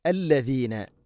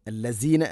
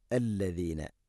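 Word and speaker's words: അല്ലദീന (الذين): അല്ലദീന, അല്ലസീന അല്ല, അല്ലദീന ആമനുത്തഖുല്ലാഹ്.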